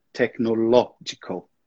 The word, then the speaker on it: technological: Almost that. Technological. The emphasis is in the next syllable.